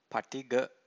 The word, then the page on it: patigha: Pali